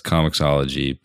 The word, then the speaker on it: Comixology: So that's Comixology, but spelled with an X, not with the ICS.